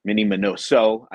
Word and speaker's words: Minnie Minoso: Minnie minosa Minnie Minoso i always pronounce his name wrong so if you hear me say minosa just you know correct me in your head and uh and then you know another one of my uh, uh collecting favorites here uh we oh, yeah, have Jethroe.